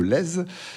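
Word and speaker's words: Lez: On prononce bien le Z, je crois. – Tout à fait. – On dit bien le Lèze, qui est un cours d'eau, le Lèze.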